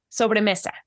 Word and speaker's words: sobremesa: And please correct me if I don't pronounce this. Sobremesa? – Sobremesa. Yeah.